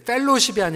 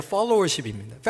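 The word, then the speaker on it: followership: 두 번째로 f o l l o w e r s 입니다 여러분 발음 잘 들으셔야 돼요. 펠로십이 우 아니라 팔로워십입니다.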